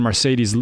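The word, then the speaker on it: Marcedes: They signed Mercedes Lewis, who, I mean, his first name is pronounced like a sports car, so you'd think he'd be good.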